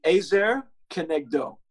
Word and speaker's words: Ezer Kenegdo: How do you pronounce that? – Yeah, so it's in the Hebrew, it would be Ezer Kenegdo.